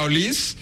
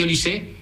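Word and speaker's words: Olise: Oye, el otro día tuve una agria polémica con los compañeros porque tú me garantizaste que se pronuncia Olís y me pusieron cortes jugador que dijo que es Olisé. – No lo sabía, me extrañaba. No, efectivamente se dice Olisé,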